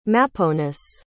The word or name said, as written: maponus